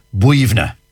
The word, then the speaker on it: Boyunagh: Well, you see, colloquially, like, for example, I know one, for example, one cemetery near Glenamedy, and it's spelt B-O-Y-U-N-A-G-H. – B-O-Y. – So you'd be saying Boyuna. No, yeah. – Not a bit of it. B-O-Y-U-N-A-G-H is pronounced Buivna.